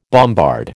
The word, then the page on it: bombard: American English